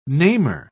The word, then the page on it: namer: British English